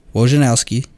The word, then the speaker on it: Wojnarowski: So I got a feeling based off of Adrian Wojnarowski. – Nice. You nailed it. Good pronunciation.